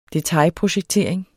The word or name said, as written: detailprojektering